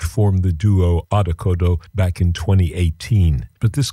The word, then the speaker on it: Odd Okodo: And they, in fact, are called Odd Okodo. I'll spell that for you. Odd is simply O-double-D, and then O-K-O-double-D-O. Odd Okodo. It's a duo consisting of German electronic experimental drummer Sven Kasirek. That's K-A-C-I-R-E-K, Sven Kasirek, and singer-songwriter and instrument maker Olith Rategó, R-A-T-E-G-O.